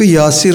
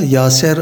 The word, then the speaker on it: Yasir: Tıpkı Yasir Arafat'a Yaser Arafat dememiz gibi. Adamın adı Yasir. Gavurlar onu Yaser diye telaffuz ettiği için biz de öyle diyoruz. Yaser Arafat diyoruz.